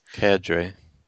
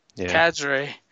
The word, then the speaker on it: cadre: Cadre. – Yeah. I kind of said cod, like with an O. Cadre.